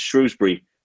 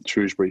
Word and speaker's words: Shrewsbury: The main thing that's just clear up for me is it's pronounced Shrewsbury and not Shrewsbury, as I've been saying it this whole time.